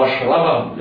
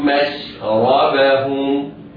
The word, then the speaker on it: مشربهم: الميم والشين مرققتان، لا يسبق اللسان وينطقها المفخمة مشربهم، لا، الميم والشين مرققتان بعدهما راء مفخمة، هكذا. قد علم كل أناس مشربهم مشربهم.